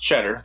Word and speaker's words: Tschetter: I do know that it's actually pronounced correctly, but it's spelled T-S-C-H-E-T-T-E-R, but it's pronounced Cheddar. The T-S apparently is silent.